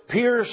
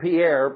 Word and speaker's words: Pierre: and Jerusalem and charted the territory that the Apostle Paul himself covered, you would find that he went as far as Pierre, South Dakota. Not Pierre, but Pierre, South Dakota, correct? Somebody went like this. All right. It's pronounced Pier, P-I-E-R, P-I-E-E, P-I-E-R-E, P-I, two E's in there, isn't there?